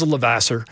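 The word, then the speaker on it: Levasseur: one being Raymond Luc Lavoisier. Am I saying that right? – He's from Maine. He just says Lavasser.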